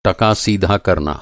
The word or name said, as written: टका